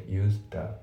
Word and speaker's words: used: で、あと発音なんだけど、 Used のところ、その D がね、抜ける感じになりますね。 Used、その後ろのザと被るんですよね。だから、Used、 used to the じゃなくて used the ですね。